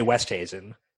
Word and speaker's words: Oosthuizen: I was told the cheat code is to say Oosthuizen. – Oosthuizen? – Yeah.